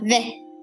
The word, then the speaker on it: vais: Now, ve. Ve, it's V A I S. Sounds a little bit like vase, like the vase you would use if you accidentally broke a vase or to hold flowers in.